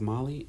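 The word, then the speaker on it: Mahli: Um, and then my last thing in the beauty products, um, I I think it's called Molly, pronounced Molly. It's M A H L I.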